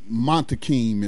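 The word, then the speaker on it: Muntaqim: Campaign parole, PLL Montanquin. Right, he was jailed at 19 years old. Jailed in 1971. – I think that's Montakim is how you pronounce his last name.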